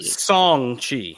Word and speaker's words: Shang-Chi: Is it Shang-Chi or Shang-Chi? – Shang-Chi. – So there's you leave the H out.